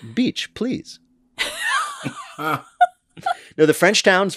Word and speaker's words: Bitche: Yes, it is the town of Beach, spelled B-I-T-C-H-E. Beach.